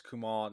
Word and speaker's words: Kumail: Kamel Nanjiani. – Yeah, you butchered it. – I butchered it? Okay. I mean, I've written it. I'm like, Kumail Nanjiani. Is that it? – I believe it's Kumail Nanjiani.